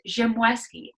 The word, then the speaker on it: Ziemlewski: Joanna Ziemlewski.